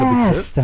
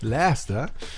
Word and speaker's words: Leicester: Und das ist, wie ich ja schon mal äh, angesprochen habe, der Lieblingsclub deines Sohnes, was die Aussprache Leicester. betrifft. – Leicester.